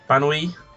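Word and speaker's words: Banoi: Yes, my second one is, I think it's pronounced Banwai? Banwai, that's it.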